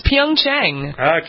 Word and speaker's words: Pyeongchang: It's not hard. It's Pyeongchang. – Okay, Pyeongchang.